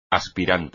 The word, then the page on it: aspirante: espagnol